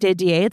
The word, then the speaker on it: Deydier: Her name is Julie Deydier, and Deydier, it's spelled D E Y D I E R, and she is in charge of all of the Chanel heritage.